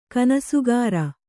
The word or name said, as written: ಕನಸುಗಾರ